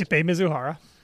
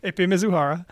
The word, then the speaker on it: Ippei Mizuhara: Ippei Mizuhara. – Thank you. So when I point to you, you say his name. So unless, point. – Ippei Mizuhara.